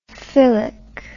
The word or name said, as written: philic